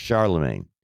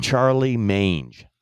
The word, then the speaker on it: Charlemagne: He couldn't pronounce Charlemagne. He called him Charlie Mange. Charlie Mange, Mark.